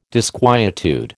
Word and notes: disquietude: American English

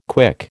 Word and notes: quick: American English

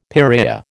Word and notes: piraya: American English

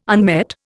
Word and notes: unmet: Indian

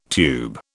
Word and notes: tube: British English

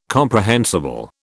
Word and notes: comprehensible: British English

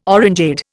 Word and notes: orangeade: Indian

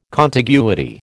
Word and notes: contiguity: American English

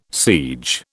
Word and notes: siege: British English

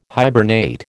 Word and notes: hibernate: American English

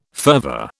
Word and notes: fervour: British English

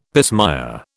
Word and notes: pismire: British English

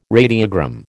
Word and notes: radiogram: American English

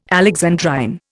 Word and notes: alexandrine: Indian

